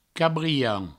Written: cabrian

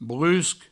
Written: brusc

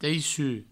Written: teissut

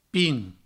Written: pin

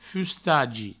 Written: fustagi

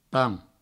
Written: pan

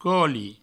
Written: coli

